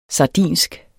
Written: sardinsk

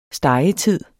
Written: stegetid

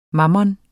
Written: mammon

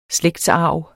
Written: slægtsarv

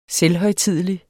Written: selvhøjtidelig

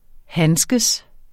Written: handske

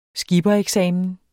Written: skipper

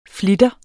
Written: flitter

